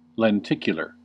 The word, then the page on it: lenticular: American English